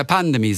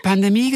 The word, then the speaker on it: Pandemie: Und siehe da, er hat es leider richtig ausgesprochen. Oh, die letzte Silbe betont. Er hat Pandemie gesagt.